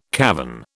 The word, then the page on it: cavern: British English